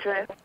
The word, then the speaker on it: Idris: How do you say his name? – It's Idris. – It is Idris, okay.